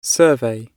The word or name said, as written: survey